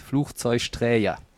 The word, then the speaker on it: Flugzeugträger: Aber das erste spricht sich wie CH, Fluch, dann Zeug, weil wir in Köln gerne Sch-Laute machen. Und Träger, weil auch vieles mit so einem J belegt wird. Flugzeugträger.